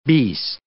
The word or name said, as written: beast